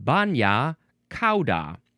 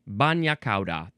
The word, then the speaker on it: bagna cauda: Ah, it is pronounced bagna cauda, which means hot sauce. Hot sauce coming through. So yes, bagna cauda. That's how you officially pronounce it.